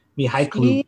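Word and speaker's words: Mykhailiuk: How do you say this guy's name? Mihailuk. – Mihailuk. That's it.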